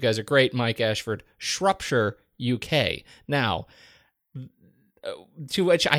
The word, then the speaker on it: Shropshire: Here we say Shropshire, as in Shropshire, as in shirt, without the T.